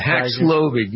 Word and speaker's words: Paxlovid: The pill from Paxlovid, yes.